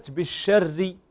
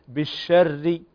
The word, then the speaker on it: بالشر: وكذلك كلمة بالشر ري، فنكسر الشفتين عند النطق بالحرف المكسور، وهنا الراء عندنا مكسورة فلا بد أن نكسر الشفتين بالشر.